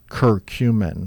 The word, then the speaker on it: curcumin: And that is spelled C-U-R-C-U-M-I-N. – Correct. – And it's pronounced? – Curcumin.